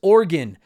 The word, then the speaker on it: Oregon: Also, it's pronounced Oregon, like your heart or liver.